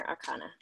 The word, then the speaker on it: arcana: major or a minor arcana? Am yeah. I saying that right? – Yes, major okay. and minor arcana.